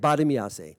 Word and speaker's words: Bartimaeus: She came over and she said, Badamiase and I would like to come. Bartimaeus, that's his name. That's how they say it, Badamiase.